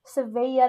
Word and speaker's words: Seville: Seville.